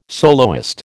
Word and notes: soloist: American English

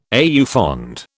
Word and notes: au fond: British English

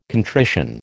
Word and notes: contrition: American English

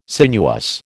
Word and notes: sinuous: American English

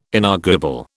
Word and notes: inarguable: British English

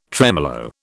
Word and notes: tremolo: British English

